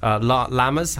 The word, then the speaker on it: llama: Llamas? How do you... – Llamas. – Llamas. – Llamas, a much, much better pronunciation.